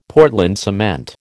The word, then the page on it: Portland cement: American English